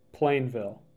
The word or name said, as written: Plainville